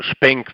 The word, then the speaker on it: XPeng: Zum Beispiel eine chinesische Firma namens XPeng, also Speng wird ausgesprochen und XPeng wird geschrieben.